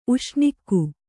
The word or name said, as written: ಉಷ್ಣಿಕ್ಕು